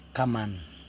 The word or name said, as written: kaman